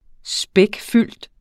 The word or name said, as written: spækfyldt